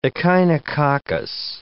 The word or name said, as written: echinococcus